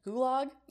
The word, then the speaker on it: gulag: Did I say that right? Is that gulag? – Yeah, that was right. – Cool.